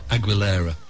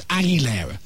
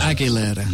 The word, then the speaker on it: Aguilera: How do you pronounce it? – Aguilera. – So it's Aguilera? – Yes. – So I am right? – Yes. – Because Dave said it was Aguilera. – No, Aguilera.